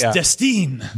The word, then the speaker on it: Destine: It's pronounced Destine. – Destine. – Well, now I can say it's like Destiny without the Y, but then people write Destine.